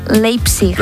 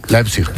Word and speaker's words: Leipzig: ¿Cómo se dice? – Leipzig. – Leipzig. Leipzig, ¿ves? – Leipzig, muy bien. – Venga, muy bien. – Es que claro, es alemana.